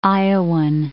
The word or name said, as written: Iowan